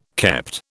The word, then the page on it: kept: British English